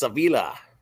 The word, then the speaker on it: Avila: So like Alex Avila, yes. Detroit Tigers, right? – Yes, same pronunciation.